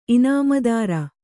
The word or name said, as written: ಇನಾಮುದಾರ